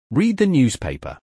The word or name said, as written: Read the newspaper